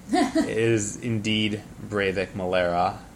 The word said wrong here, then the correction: Valera: Or Valera. I knew Valera. I just was stupid, and I said it incorrectly.